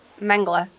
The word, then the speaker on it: Mengele: How do you pronounce it? – I pronounce it Mengele.